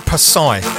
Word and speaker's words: Posij: Now, his name might be spelt P-O-S-I-J, but you pronounce it Posai.